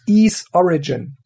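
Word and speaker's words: Ys Origin: Und zwar ist es Ease Origin. Geschrieben großes Y, kleines S.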